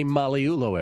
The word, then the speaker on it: Magliulo: All right, Vinny Magliulo, everybody. That's how you actually pronounce his name.